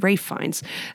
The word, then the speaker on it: Ralph Fiennes: Always throws me off that it's spelled Ralph. But Ralph Fiennes, and Anya Taylor-Joy, Nicholas Hoult.